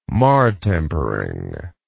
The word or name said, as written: martempering